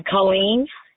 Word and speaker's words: Colleen: Colleen, C-O-L-L-E-E-N, Quigley, Q-U-I-G-L-E-Y, my son Riley, my adult son. Riley, R-I-L-E-Y.